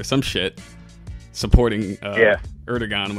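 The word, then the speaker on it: Erdoğan: Erdogan. – Erdogan.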